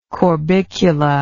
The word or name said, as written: corbiculate